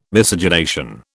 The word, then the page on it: Miscegenation: British English